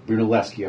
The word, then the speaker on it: Brunelleschi: And they go back to the Brunelleschi thing, if that's the right way to pronounce it. – Brunelleschi, of course I would do that.